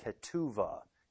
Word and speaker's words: ketuvah: In Hebrew, this betrothal contract is called ketuvah, K-E-T-U-V-A-H. Some spell it with a B there instead of a V.